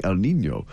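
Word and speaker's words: El Niño: El Nino.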